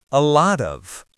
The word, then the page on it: a lot of: American English